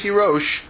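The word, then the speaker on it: Tirosh (תירוש): It's written in the Torah Tirash without any Vav, right? The word, he's doing word games. But we read this word according to the pronunciation handed down by tradition as Tirosh.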